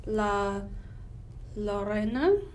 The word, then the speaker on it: La Llorona: The Curse of La Llorona. Mm. Did I pronounce that correctly? – You know you did.